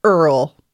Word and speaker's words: Earl: His name is URL, and it's actually pronounced Earl.